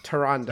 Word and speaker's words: Tyrande: I can never pronounce Tyrande. – Yeah, I see it. I've heard it. And then I mispronounce it. I'm sorry, Tyrande.